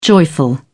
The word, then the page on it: joyful: British English